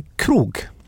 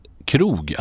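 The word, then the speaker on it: Krug: Då, det är alltså en krog. – För att få till uttalet så ska man tänka alltså som svenska krog, alltså ett krogbesök. – Mm. – Det är där någonstans man ska sikta.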